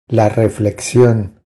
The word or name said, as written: La reflexion